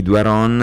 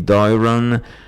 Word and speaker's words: Doiron: Lei si chiama Julie Doiron, o se preferite questa pronuncia, Julie Doiron.